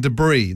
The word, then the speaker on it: debris: Number 5, you don't pronounce the S in debris. This is a bit like salmon. Who says debris? – Wait, there's an S in debris? – Well, it's spelled D-E-B-R-I-S.